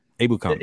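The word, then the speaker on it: Ebukam: Samson Epkebon, is how do you say his last name? I'm sorry, is it Epkebon? – Abukam, yeah.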